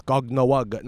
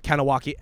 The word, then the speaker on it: Kahnawake: Now, again, some people were saying you're not pronouncing it right. It's Gognawug, not Kahnawake.